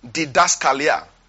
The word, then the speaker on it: didaskalia: Now, one of the shades, so number one shade of that word is the word didaskalia. Didaskalia. didaskalia. I'll spell it for you. D-I-D-A-S-K-A-L-I-A.